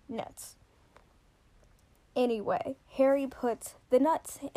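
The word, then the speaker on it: Knut: And it's spelled K-N-U-T-S, so I'm going to pronounce it nuts, but I know some people say knuts, but I don't think that sounds as good.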